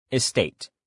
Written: estate

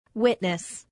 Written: witness

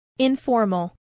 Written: informal

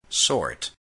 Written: sort

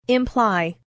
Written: imply

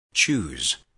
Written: choose

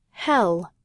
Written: hell

